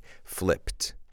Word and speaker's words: flipped: And remember, this is a regular verb, but in the infinitive, the P doesn't require the use of our voice. In other words, if I say flip, the sound of the P, flip, I don't use my voice to pronounce that sound. So that means in the past, it sounds like a T, flipped.